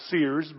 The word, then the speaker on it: Sayers: I came across a quote in my studies this week from Dorothy Sayers, who she liked to pronounce her name Sears, but looks like it's Sayers.